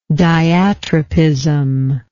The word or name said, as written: diatropic